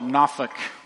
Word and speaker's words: Norfolk: The locals call it Norfolk. That's the way they pronounce it.